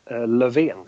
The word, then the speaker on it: Löfven: Sorry, I probably mispronounced it, but your Prime Minister. – The last name. – Is it Lofgren? Lofin? – Löfven.